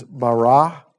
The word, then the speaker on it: bara: The Hebrew word for the word created is bara. Okay, I don't know if you guys that took Hebrew or if I'm saying that right, but it's like it's B-A-R-A, but like the pronunciation is like B-A-W-R-A-W, bara.